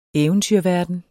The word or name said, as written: eventyrverden